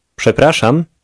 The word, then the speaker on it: przepraszam: Przepraszam. Przepraszam. – The sh sound in that word is common in Polish.